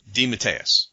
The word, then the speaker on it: DeMatteis: Yeah, I asked him. I said, how do you pronounce it? And he actually gave me two different variations. So I'm going with DeMatteis.